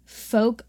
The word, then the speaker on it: Fouke: So the legend of the Boggy Creek Monster has long captivated the people of Folk, Arkansas. It's spelled F-O-U-K-E. So. – Okay. – Okay. Fun fact. Okay. I wouldn't have guessed folk. – No. F-O-U-K-E.